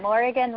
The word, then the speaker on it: Morrighan: Yeah, that, I tell people Morrighan rhymes with Oregon, so it kind of helps them know how to say okay.